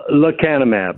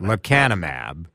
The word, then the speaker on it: Lecanemab: Lecanemab. – Lecanemab.